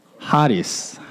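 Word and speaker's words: Haris (χάρις): Okay, it's pronounced actually like, no, nice try. Haris, Haris, right? It's a, a, the C is silent, right?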